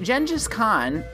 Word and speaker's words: Genghis Khan: And you'd be right, except Genghis Khan, and yes, that is how you pronounce it, I looked it up, was a lot more complicated than genocidal maniac with a lot of wives.